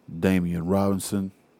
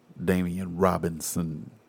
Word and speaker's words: Damian Robinson: I'm your host, uh, your kinfolk, Damian Robinson. Did I say that right? You know, sometimes I don't really say the, you know, the, the B kind of just like slide out, but Damian Robinson, you know, I really be like Damian Robinson, you know what I'm saying?